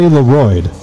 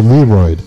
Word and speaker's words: LeRoid: Because everything that rhymes with Royd, right? – Yeah. – Like, I hated my name at one point. And that's why I couldn't say Leroyd. So I'd say Leroyd.